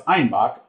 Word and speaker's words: Einbeck: And then due to their Bavarian accent, citizens of Munich pronounced Einbeck as Einbach or a billy goat.